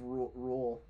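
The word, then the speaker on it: Reuel: John Ronald Rule. – Rule? – I'm pretty sure it's Rule. – Rule? – It's R-E-U-E-L.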